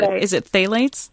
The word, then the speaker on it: phthalates: I gather the danger here is, I don't know if I'm going to be pronouncing this right, it has too many consonants at the beginning, but is it phthalates? – That's the way I say it.